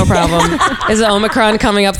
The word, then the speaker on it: Omicron: am I saying it right? – Yeah. – Omicron yeah.